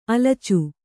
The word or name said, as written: ಆಲೋಚ